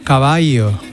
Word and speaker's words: caballo: Qué bonito que era escuchar de vez en cuando caballo. – Caballo, ¿no? Que es como realmente se pronunciaba la L.